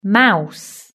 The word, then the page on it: mouse: British English